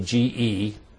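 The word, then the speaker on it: ge (γῆ): Now, the word earth is the word ge, G-E. It's pronounced ge in the Greek language.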